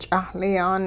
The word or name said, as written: ch'ahli-yaan